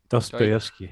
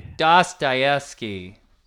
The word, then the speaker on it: Dostoevsky: Dotrevesky. Travesky, Doy Doy Dostoevsky. – Dostoevsky. – Yeah.